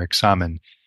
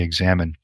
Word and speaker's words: examen: Now, this word examine itself is just the English pronunciation of the Spanish word that St. Ignatius used for this form of prayer, examen, in the Spanish, and we just say examine.